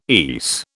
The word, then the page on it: ESE: British English